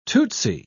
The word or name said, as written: tootsie